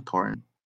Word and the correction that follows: Horton Tucker: Talen Horton Tucker.